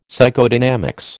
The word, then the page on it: Psychodynamics: American English